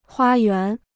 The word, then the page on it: 花园: huā yuán